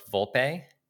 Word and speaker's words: Volpe: I think I heard it on your audiobook, Volpe.